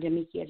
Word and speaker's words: Damikia: it's A-M-B-I-A-N-C-E. And on Facebook, I'm Damiquia Jackson. It's just my name. – Okay. – Oh, cool, it's spelled D-A-M-I-K-I-A.